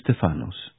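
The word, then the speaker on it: Stefanos (Στέφανος): Jego imię po grecku wymawia się Stefanos, a oznacza wieniec.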